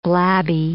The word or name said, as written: blabby